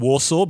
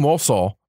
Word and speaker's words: Walsall: So although I will diverge slightly to say, don't worry about the fact that you're struggling to pronounce Walsall, because even most British people struggle to pronounce Walsall. They keep going Warsaw because that's the more, it's similar and it's the big city in Poland.